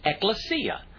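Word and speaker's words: ekklesia (ἐκκλησία): Well, this word church comes from a Greek word that is pronounced ekklesia.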